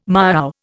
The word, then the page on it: miaow: Indian